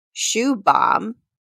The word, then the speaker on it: Shubham: A friend of mine's name is pronounced Shoe Bomb, which is spelled S-H-U-B-H-A-M.